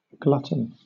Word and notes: glutton: British English

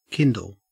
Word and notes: kindle: Australian English

